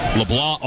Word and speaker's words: LeBlanc: It is spelled like LeBlanc, L-E-B-L-A-N-C. He's from Maurice, Louisiana, though, and it is pronounced LeBlanc.